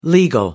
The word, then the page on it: legal: American English